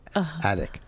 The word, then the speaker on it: addict: Do you know how to spell it? – A-D-D-I-C-T. – Yeah, so you got to say the T.